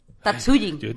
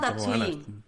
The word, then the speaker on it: Tatsujin: El de los tambores, Taiko no Tujutsu o algo así, es que no sé cómo se dice. Tatsujin. taiko no Tatsujin. – Taiko, no Tatsujin.